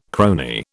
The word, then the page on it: crony: British English